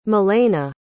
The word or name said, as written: melaina